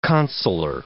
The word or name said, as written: consular